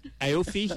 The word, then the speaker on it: Aoife: You'll have to pronounce this A-O-I-F-E.